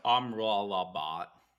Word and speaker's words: Amrabat: I believe it's pronounced Amrabat, right? – That's what it is.